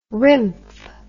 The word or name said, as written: remf